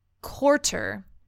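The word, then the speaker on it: Koutr: Okay, so what I got was I think the way to pronounce this is quarter. – Okay. – It's K-O-U-T-R. Quarter.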